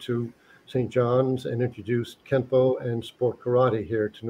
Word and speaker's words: Kenpo: In Japanese, it's pronounced as Kenpo.